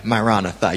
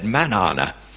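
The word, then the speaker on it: maranatha: You could easily turn around and say, well, you didn't say marana tha. You said manana, which is wrong. That just rhymes with banana.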